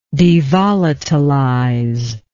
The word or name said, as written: devolatilization